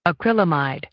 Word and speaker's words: acrylamide: Acrylamide. – Acrylamide. – Acrylamide. – So it's called acrylamide or acrylamide. I was totally off on that one. – Acrylamide. So this or is, acrylamide. – This is your word of the day. Acrylamide. A-C-R-Y-L-A-M-I-D-E, acrylamide.